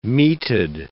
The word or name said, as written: meated